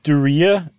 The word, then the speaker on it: Duryea: And so they tried this with John and Louise Davis from the, how do you pronounce that car? – Duryea. It sounds like something you contract that you'd be really unhappy about. – Okay, the Duryea, I couldn't quite tell, but yeah, it does sound like a disease of some sort.